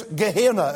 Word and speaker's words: Gehenna: Now, pronunciation-wise, the word for hell here is Gehenna.